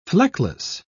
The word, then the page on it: fleckless: British English